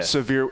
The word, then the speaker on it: Sevier: Sevier, it's like S-A-H-V-I-R.